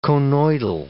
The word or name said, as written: conoidal